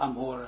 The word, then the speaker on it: Amora: En Amora zeggen we. Moeten we goed uitspreken dat niet gewoon zoals het staat in de Torah, de plaats is Amora.